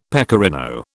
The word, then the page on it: pecorino: British English